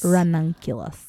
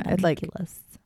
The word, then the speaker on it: ranunculus: How do you say it exactly? – Ranunculus? – Ranunculus. – Yeah. Ranunculus.